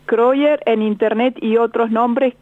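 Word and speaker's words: Krøyer: Pero, por ejemplo, el más conocido es Kroyer. – ¿Cómo se escribe eso? – Con una K, Kro. – Sí, Kro. – Y-E-R, que te lo pronuncio de la manera más castellana posible.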